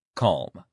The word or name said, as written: calm